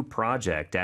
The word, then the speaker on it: project: I started a new project at work. – I started a new project. P-R-O-J-E-C-T. – 그렇죠. 프로젝트인데 발음이 조금 다르죠. Project. 이렇게 발음한다는 것도 기억해 주시고요.